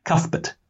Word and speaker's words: Cuthbert: How do you say his name? – Cuthbert.